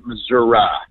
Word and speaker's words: Missouri: If you're in around Kansas City, it is Missouri. If you get much further south of here, a lot of people pronounce it Missouri. – That doesn't help me out at all, Congressman. So it depends on where you're trying to get votes. If I'm down in the southern part of my district, sometimes I'll slip into Missouri, up around the Kansas City area. They pronounce it more of Missouri.